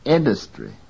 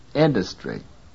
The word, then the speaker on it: industry: 11. Listen to the quality of the vowel in the same syllable in this word. Industry. Industry. 12. The syllable dus is weakly stressed in this word and consequently occurs in a reduced form.